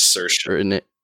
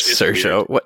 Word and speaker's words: Saoirse: Her name's Saoirse. She's Irish. – Saoirse. That is not how it's spelled. Yeah, so.